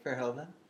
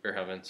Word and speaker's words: Verhoeven: But Paul Verhoeven, or Verhaven. – I think Verhoeven. – Verhoeven. – So that's how I've been saying it. – We'll say Verhoeven.